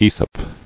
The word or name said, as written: Aesopic